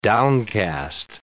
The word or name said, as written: downcast